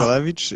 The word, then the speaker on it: Góralewicz: You're very close. It's Bartosz oh, ah. Góralewicz.